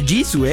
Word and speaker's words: Jisoo: Lisa, Jisoo, que se pronuncia Jisoo, ¿eh? Esa solamente lo sabemos los grandes fans del K-pop.